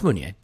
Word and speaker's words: Meunier: So one of the rumors is Meunier, or Meunier. I guess it sort of depends if you want to go with the French pronunciation there or not. And no, it's Meunier.